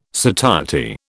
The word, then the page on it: satiety: British English